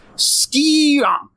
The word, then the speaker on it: SKREEEONK: And that is Skionk, which is spelled S-K-R-E-E-E-O-N-K.